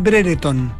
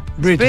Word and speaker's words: Brereton: Tú sabes que es Britton, ¿no? – ¿Ah? – No es Brereton, Britton. Es Britton. – Britton. Sí, pues, somos very British nosotros. – Britton.